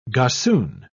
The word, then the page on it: gossoon: British English